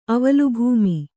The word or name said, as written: ಅವಲುಭೂಮಿ